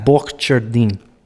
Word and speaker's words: Borcherding: I usually say butchering. I'm going to butcher it anyway, so it's Jack Butchering.